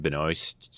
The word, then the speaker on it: Benoist: Is that how you say her name? Benoist? Something like that. I heard it's like moist, but Benoist.